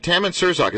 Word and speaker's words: Tammin Sursok: We know his acting on Seinfeld and Tammin Sursok.